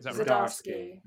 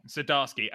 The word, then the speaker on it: Zdarsky: How do you pronounce his last name? Chip Zdarsky? – Zdarsky. – Zdarsky.